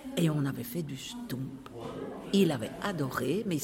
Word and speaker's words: stoemp: Le Stump, S-T-O-E-M-P. Une première difficulté qui peut se présenter pour un touriste qui lit un menu de restaurant sera la prononciation du mot Stump, qui, bien que s'écrivant Stoemp, se prononcera bien Stump.